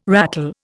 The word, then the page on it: ratel: Indian